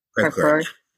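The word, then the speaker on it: Creve Coeur: Now, I know that Crevecoeur, is that how you say it? – Crevecoeur. – Yeah.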